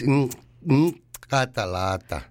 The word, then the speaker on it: Nkatalata: It's Nkatalata. – Nkatalata. Yeah. You gotta put a little click there.